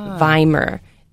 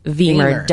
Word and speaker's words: Wiemer: When there are two vowels side by side, so you'll notice Leitz is L-E-I-T-Z, we always pronounce the latter vowel. So it's not Leitz and it's not Wiemer. It's so it's Wiemer, W-I-E-M-E-R.